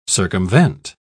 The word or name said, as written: circumvent